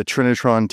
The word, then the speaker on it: Trinitron: The Trinitron TV.